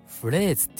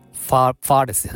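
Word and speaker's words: phrase: パースじゃないですね、フレーズって読むんですね、これは。フレーズって言うんだったらわかります。皆さん、ファーレスじゃないですよ。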